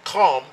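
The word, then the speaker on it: Kham (חם): "And the land where Ham went," Kham, that's how you pronounce Ham in the Hebrew, Kham, "is hot."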